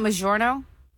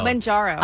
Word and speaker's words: Mounjaro: It's spelled M-O-U-N-J-A-R-O. – Isn't it Majorno? Like no. – Mount Jaro. – Manjaro. – I was saying Manjaro. – Manjaro? – But it's Mound, M-A-W-N, pronunciation.